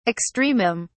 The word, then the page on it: extremum: British English